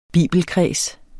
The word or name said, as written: bibelkreds